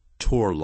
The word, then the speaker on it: toward: 那么这里我特别要提醒，就是 attitude 这个字，那么它后头以介词 toward 并用 T-O-W-A-R-D T-O-W-A-R-D，我不太喜欢他对人生的那种态度。 I don't like His attitude toward life，这个字变念成 toward 啊，这样老外不这么念的，toward， T O W A R D， toward。